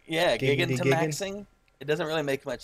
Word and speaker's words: Gigantamaxing: It's G-I-G-A-N, right? Gigan, I would say. And then T-A-M-A-X-I-N-G. Gigantamaxing.